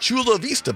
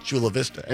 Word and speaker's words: Chula Vista: So Chula in Spanish would be Chula. That's how you say it. Vista in Spanish would be Vista. So right. – It should be like Chula Vista, baby. But no, it's Chula Vista. Anyway, but the key word for the 1 o'clock hour, Chula, C-H-U-L-A.